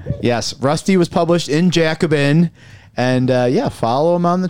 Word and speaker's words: Jacobin: As you were published in this magazine, Rusty, how do you pronounce it? Is it Jacobin? – That's the one. It's Jacobin.